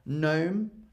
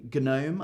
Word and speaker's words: gnome: Gnome, I don't say gnome, I say gnome.